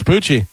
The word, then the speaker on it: Pucci: It's Poochie. And it's spelled P-U-C-C-I.